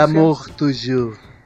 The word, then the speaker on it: L'amour toujours: Oye, esta canción, L'amour toujours, así se pronuncia. L'amour toujours.